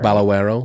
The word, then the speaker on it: Balagueró: And then in an interview with Fangoria, uh, direct the director Balagueró, I think I said it right. Balagueró.